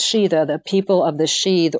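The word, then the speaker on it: síd: And in early Irish sources, it was referred to as the realm of the sheath, and that's spelled S-I with an accent, D, sheath.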